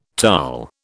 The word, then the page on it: Taal: British English